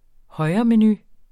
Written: højremenu

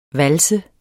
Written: vals

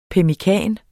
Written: pemmikan

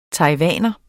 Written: taiwaner

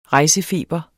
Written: REJSEFEBEREN

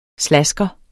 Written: slasker

